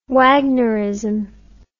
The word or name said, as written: Wagnerism